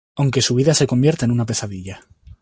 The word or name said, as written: convierta